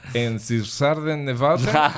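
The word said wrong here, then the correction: Einstürzende Neubauten: Einstürzen de Neubauten.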